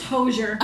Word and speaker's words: Hozier: He's Irish. It's Hozier. I don't know. I think that's how they said it on Saturday Night Live.